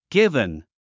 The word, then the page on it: given: ɡívən; ギブン